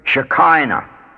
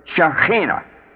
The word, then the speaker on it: Shekinah: One of the butchered pronunciations is Shekinah. Its real pronunciation is Shekinah. The K is guttural.